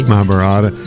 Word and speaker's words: Mahabharata: How about the Mahabharata? Do I say that correctly? – Mahabharata. Yeah, but that wasn't one.